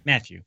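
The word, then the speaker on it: Mathieu: Isn't it just pronounced Matthew, but everyone says Matthew? It's just Matthew, right? – Matthew, correct.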